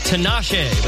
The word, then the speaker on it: Tinashe: Is it Tinashe? – Yes, well, you know. – That's right. – Oh, yeah. – Actually, it's pronounced Tinashe, but that's all right. It's close enough.